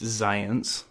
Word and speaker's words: Xyience: It actually says on the back, it's pronounced Zions. – It's spelled X-Y-I-E-N-C-E.